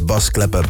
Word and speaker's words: Bas Klepper: Actually, his real name is Bas Klepper, but yeah, if you pronounce that in English, it sounds like Bass Clef.